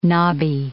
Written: knobby

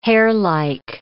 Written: hairlike

